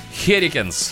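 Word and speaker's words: Hurricanes: В общем, 22 февраля прошли очередные матчи Национальной хоккейной лиги, и во время которых которых команда Каролина Херинекс, э, правильно сказала? – Херикенс. – Херикенс. Херикенс.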